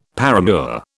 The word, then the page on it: paramour: British English